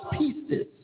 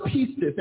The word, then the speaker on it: pistis: As I did my research and was looking at the Greek word for faith is pronounced pistis. Uh-huh. It's pronounced pistis, and it simply means belief.